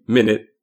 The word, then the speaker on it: Minit: Conceit of the game is that, like the name, Minute, even though it's not spelled the same, it is spelled M-I-N-I-T, you have 1 minute to play.